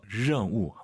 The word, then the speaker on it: 任务: 알 발음 런 아니고 더 혀를 뒤로 보내서 任务 한번 더.